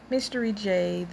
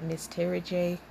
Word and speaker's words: Mys_terriJ: Now, I know a lot of y'all would pronounce that Miss Terry J, which is perfectly fine. It's pronounced mystery but some of y'all say Miss Terry. It's all the same. It's pronounced mystery because, of course, I'm the one that came up with it.